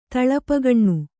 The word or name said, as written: ತಳಪಗಣ್ಣು